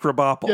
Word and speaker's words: Krabappel: It's all, everybody knows it's pronounced Krabappel.